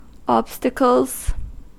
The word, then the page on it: obstacles: American English